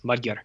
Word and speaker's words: Magyar: I have perfect pronunciation of Hungarian words. Magyar.